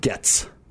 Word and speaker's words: Goetz: Dave Goetz? Goetz? – Getz. – Oh, man. – Yes, we should. But it is spelled that way.